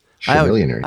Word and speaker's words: Chamillionaire: Chamillionaire. – I always called him Chamillionaire. I don't know. But Chummeaner, like Cha-Cha Slide. – Yeah, it's a CH.